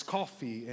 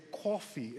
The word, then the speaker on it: coffee: We learned about the East Coast accent and, you know, how they pronounce coffee, and they say it like this. They say coffee instead of coffee, or they will say, instead of tournament, they will say tournament, like it's tournament, not tournament. It's coffee, not coffee, all right?